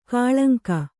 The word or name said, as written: ಕಾಳಂಕ